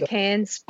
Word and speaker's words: Cairns: We say Cairns.